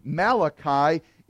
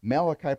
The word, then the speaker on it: Malachi: In fact, in the Hebrew, Malachi is pronounced the same way you would say messenger.